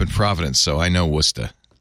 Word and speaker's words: Worcester: Worcester, Massachusetts.